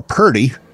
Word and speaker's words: Purdy: In hour two, Dr. Laura Purdy, P-U-R-D-Y, not pretty. I'm not mispronouncing pretty, although she is lovely.